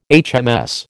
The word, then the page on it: HMS: American English